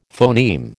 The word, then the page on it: phoneme: American English